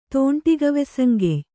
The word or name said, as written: ತೋಂಟಿಗವೆಸಂಗೆಯ್